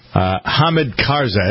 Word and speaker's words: Hamid Karzai: In the meantime, Hamid Karzai, that's how you pronounce his name, right? – Sure.